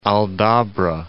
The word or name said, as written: Aldabra